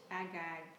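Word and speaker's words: ag-gag: AGAG, am I saying that correctly? – Yes.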